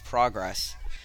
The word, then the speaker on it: progress: No, I'm saying that the pronunciation here infers it's a different word. – You can edit that. – It's not progress, the noun.